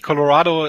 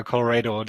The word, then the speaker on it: Colorado: Na, es ist tatsächlich, Colorado ist richtiger als Colorado. Der Deutsche tendiert manchmal gerne dazu, Colorado ist etwas zu überamerikanisieren.